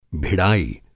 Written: भिड़ाई

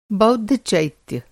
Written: चैत्य

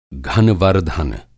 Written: घनवर्धन

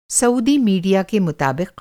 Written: सऊदी

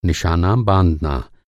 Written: निशाना

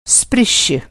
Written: स्पृश्य

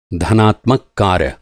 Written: धनात्मक

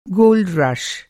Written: गोल्ड रश

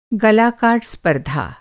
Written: स्पर्धा

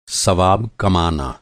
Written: सवाब

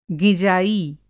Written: गिँजाई

